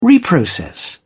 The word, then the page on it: reprocess: British English